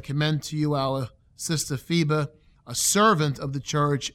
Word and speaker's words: Phoebe: I know some people say Phoebe, but supposedly the pronunciation is Phoebe.